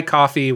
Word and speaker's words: Ko-fi: So brave for not pronouncing that URL. – Oh, yeah. Uh, Ko-fi, coffee, whatever.